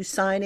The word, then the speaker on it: SIGND: So this is a new initiative, and it's spelled SIGND.